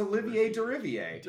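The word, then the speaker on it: Olivier Derivière: The music for Plague Tale Requiem was composed by, I'm going to butcher this, Olivier Derivier? – It's Olivier Derivier.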